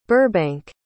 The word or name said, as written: Burbank